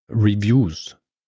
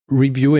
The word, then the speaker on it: Review: Und dann habe ich es geändert und es ist natürlich tatsächlich komplizierter, weil das Verb, da ist die Betonung auf der zweiten Silbe. Review, ja. To review. Aha. Und wenn es aber das Hauptwort ist, dann ist es Review.